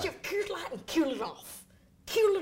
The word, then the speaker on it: Coors Light: You're even saying it correctly. Coors Light.